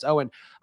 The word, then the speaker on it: Eoin: Owen in the chat, who is spelled Eoin, but I learned on Friday it's Owen.